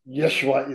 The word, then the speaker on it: Yeshua: Yeshua is how it's pronounced in Aramaic.